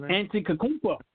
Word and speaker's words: Antetokounmpo: Giannis Antetokounmpo. – I still don't know what you're talking about. Antetokounmpo.